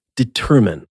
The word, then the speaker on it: determine: If underline keeps the ein pronunciation of the I-N-E suffix, it's because the first syllable of the word is stressed. Underline. With determine, however, the emphasis falls on the second syllable, determine.